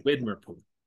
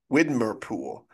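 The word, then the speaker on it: Widmerpool: I saw some interview with him where he's always, he was constantly asked who's Winder, I can't say his name, Widmer Pool. – Widmer Poole.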